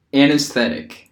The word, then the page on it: anesthetic: American English